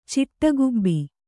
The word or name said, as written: ಚಿಟಗುಬ್ಬಿ